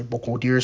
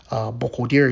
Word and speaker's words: Burkholderia: Bocoderia pseudomallei. Bocoderia is spelled B-U-R-K-H-O-L-D-E-R-I-A, Bocoderia. And then pseudomallei is spelled as pseudo, P-S-E-U-D-O, and then M-A-L-L-E-I, pseudomallei, right?